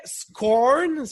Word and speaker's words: Scorn: Euh, il y avait Scorn.